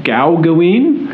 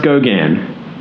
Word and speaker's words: Gauguin: Gauguin, actually pronounced Gauguin.